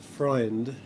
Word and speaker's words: Freund: Okay, well, my name is pronounced Freund, and it's the German word for friend.